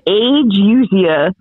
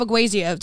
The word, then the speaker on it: ageusia: I don't know how you say this. It's like agusia. How it's spelled. Hold it's A-G-E-U. It tells me how to pronounce it, and I still don't know how to pronounce it. – Spell it for me. – A-G-E-U-S-I-A. – Agusia. – Yeah.